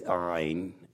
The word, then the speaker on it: Ayin: The letter Ayin means just what it sounds like. I. It's Ayin. It's Ayin.